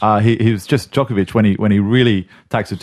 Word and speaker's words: Djokovic: Djokovic, that's right. We'll get the pronunciation right.